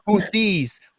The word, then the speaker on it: Houthis: The Houthis. Where'd the Houthis... – Yes. – All of a sudden, the Houthis are a thing. Houthi and the Blowfish. – It's the Houthis. – Oh, the Houthis.